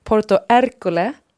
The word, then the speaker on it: Porto Ercole: Porto Ercole.